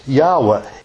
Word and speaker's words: Yahweh: And some of the more modern scholars say it's more likely that it would be pronounced Yahweh.